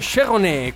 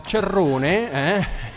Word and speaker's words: Cerrone: Now, Ena hola lie, ena hola lia, così che dice il ritornello di questo stupendo brano di Cheronet, così che dobbiamo apostrofare, alcuni lo chiamano Cerrone, noi vogliamo chiamarlo con la pronuncia francese,